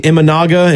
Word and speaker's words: Imanaga: Ritchie up against the Imanaga. Is that, am I saying that right? – I think so.